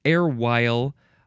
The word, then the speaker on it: erewhile: I guess you have to emphasize the second syllable again. Erewhile.